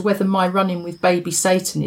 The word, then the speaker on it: Sayton: This morning I delivered little baby S-A-Y-T-O-N, pronounced Satan, as in King of the Underworld.